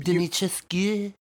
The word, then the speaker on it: Dimitrescu: Dimitrescu? Is that how you say it? Dimitrescu? – You really exaggerated it, but you did pronounce it correctly.